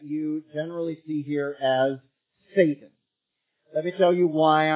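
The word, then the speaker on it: Satan (שָׂטָן): but Satan is how you say it in Hebrew, and this has the definite article before it.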